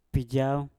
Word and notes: pijauw: mpur

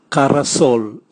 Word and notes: karasol: Raga

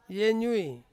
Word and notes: yenyui: Ansus

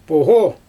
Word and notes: poGo: Xyzyl

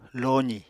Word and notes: lozì: Apatani

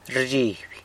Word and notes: rzhiby: gets scared

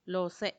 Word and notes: lose: Macuiltianguis

